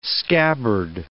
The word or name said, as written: scabbard